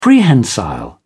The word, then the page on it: prehensile: British English